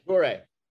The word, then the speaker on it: Dore: Dore.